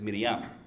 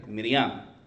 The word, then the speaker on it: Miriam: They pronounce it Miriam. You see the Passion of the Christ? What do they keep on calling Mary? Miriam. Okay, that's how you say it in Hebrew, and even Arabic too.